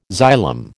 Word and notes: xylem: American English

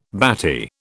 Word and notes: batty: British English

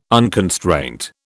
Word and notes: unconstraint: British English

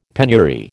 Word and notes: penury: American English

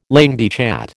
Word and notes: langue de chat: American English

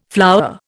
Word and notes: flour: Indian

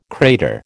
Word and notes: crater: American English

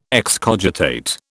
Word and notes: excogitate: British English

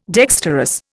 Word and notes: dexterous: Indian

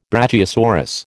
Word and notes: brachiosaurus: American English

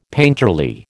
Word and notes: painterly: American English

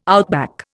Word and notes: outback: Indian

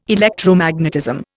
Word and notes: Electromagnetism: Indian

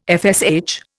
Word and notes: FSH: Indian

